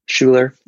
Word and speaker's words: Schuler: Yeah, you got it pretty much right. Schuler, as we say it here in the States. It is German, so pretty sure you're pronouncing it correctly.